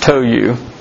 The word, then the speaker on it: Tou: When Tohu, and that's how it's pronounced, Tohu, king of Hamath, which Hamath is in northern Syria.